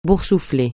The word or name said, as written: boursouflé